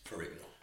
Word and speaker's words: Ferrigno: Ferrigno.